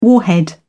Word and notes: warhead: British English

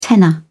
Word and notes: tenner: British English

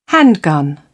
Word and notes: handgun: British English

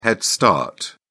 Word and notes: head start: British English

